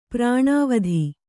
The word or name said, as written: ಪ್ರಾಣಾವಧಿ